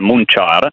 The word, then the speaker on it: Munchar: Vabbè, Montechiaro in dialetto locale si chiama Munchar,